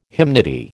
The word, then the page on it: hymnody: American English